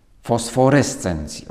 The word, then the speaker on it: fosforescencji: Ale, ale z, używając tego właśnie porównania do fosforescencji, fosforescencji, o tak to się powinno wymawiać,